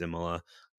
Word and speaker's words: Imola: I think you pronounced the last one correctly, but it's Imola.